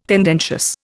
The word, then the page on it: tendentious: Indian